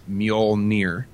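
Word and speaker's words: Mjölnir: The pronunciation is Mjolnir. – Mjolnir, okay. – Mjolnir.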